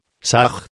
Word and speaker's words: SAH: AM se escribe con las letras H, A, M. SA se escribe con las letras S, A, H. La H suena siempre como J.